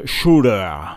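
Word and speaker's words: Tsjuder: Alors pour ceux qui ne connaissent pas, car c'est le dernier album en date de 2015 euh, déjà, ça se prononce Shooter.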